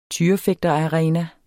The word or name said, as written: tyrefægterarena